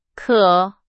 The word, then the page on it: 渴: Chinese